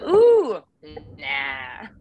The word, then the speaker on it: Oona: It also comes with the hand gestures of ooh, nah.